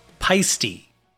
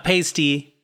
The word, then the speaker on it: Paiste: It's pronounced paiste. Not pasty, not paste, paiste.